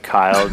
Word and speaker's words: Kyle: You can find me on Twitter at Now You See Me, with C spelled like my name, S-I-E. – You can find me at Kyle Demetrius. For all the people that don't know Eastern European names, it's K-Y-L-E-D-E-M-E-T-R-I-U-S.